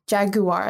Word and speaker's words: jaguar: Jaguar is how you say it in the UK, right? Jaguar. Jaguar. That's how I would say it.